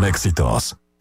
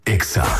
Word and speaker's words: EXA: Vive el verano loco en H-E-B. – No se dicen éxitos, se dice EXA.